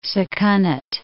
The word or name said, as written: Sakonnet River